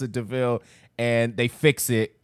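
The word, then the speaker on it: De Vil: It's called a devil. DeVille. It's pronounced DeVille.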